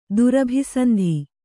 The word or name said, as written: ದುರಭಿಸಂಧಿ